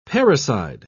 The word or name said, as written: parricide